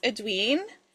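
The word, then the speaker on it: Aeduan: And I think it's pronounced Edwin from the Witchland series by Susan Dennard.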